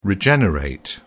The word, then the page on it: regenerate: British English